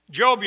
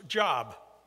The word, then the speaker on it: Job: Job, you know, is back there in the Old Testament. It's the book in the Bible that you probably pronounced Job, but it's pronounced Job.